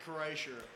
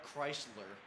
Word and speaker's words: Kreischer: It's Burt Kreischer, or as some people say, Bart Chrysler.